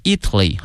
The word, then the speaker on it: Italy: City 啊，武汉市的一个名片吧哈，叫做看看这个热干面的英文翻译叫 hot dry noodle 啊，特别容易哈，hot 热，dry 干 noodle 哈、啊，热干面 has been taken to Italy by a couple，哎，被带到了意大利哈，Italy，哎，意大利这个词啊，同学们要小心了，它的名词和它的形容词呢，这个发音同学们一定要小心了。意大利呢是 Italy 啊，国名。如果说意大利的或意大利人叫 Italian，哎，所以说这个发音还是有区别的。